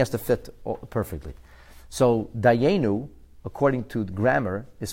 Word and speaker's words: Dayenu: So since it's pronounced Dayenu as a tzere, as if it would have two Yuds, so therefore the Rebbe says that fits the teaching of the Zevach, of the Mishnah Schlitz nonetheless.